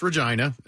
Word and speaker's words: Regina: It's pronounced Regina, not Regina.